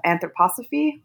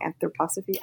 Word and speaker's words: anthroposophy: He um, founded a philosophy called anthrop- uh, anthroposophy. I'm saying that wrong. Anthroposophy.